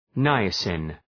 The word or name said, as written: niacin